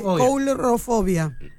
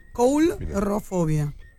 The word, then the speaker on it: Coulrofobia: Mirá cómo se dice. Coulrofobia. Coulrofobia.